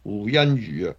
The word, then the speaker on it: 胡鑫宇: 啊，我哋廣東話讀音胡鑫宇啊，咁呢個人咧就。